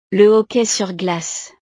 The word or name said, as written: le hockey sur glace